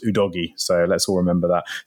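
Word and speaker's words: Udogie: First of all, Destiny has said that his surname is pronounced Ooh Doggy.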